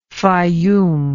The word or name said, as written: faiyum